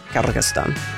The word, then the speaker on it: Kyrgyzstan: As a result of that, Kazakhstan, Uzbekistan, Tajikistan, Kyrgyzstan. Did I get that right? Kyrgyzstan.